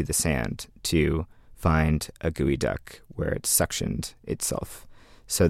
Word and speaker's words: geoduck: And the name geoduck is commonly mispronounced geoduck, which is how it's spelled, G-E-O-D-U-C-K,